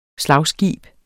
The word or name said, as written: SLAGSKIBE